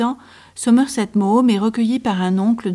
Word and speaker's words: Maugham: Somerset Maugham, ce nom qu'on écorcherait volontiers en français par Somerset Maugham. Mais non, il faut bien prononcer ainsi, Maugham.